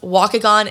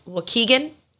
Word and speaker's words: Waukegan: Apparently, it's not pronounced Waukegan. It's Waukegan. I think that's how you pronounce it.